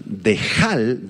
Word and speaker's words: dehal: Esta palabra espantosa, dehal, del arameo, D-E-H-A-L, con acento en la A, dehal, apunta hacia un ser formidable que produce terror.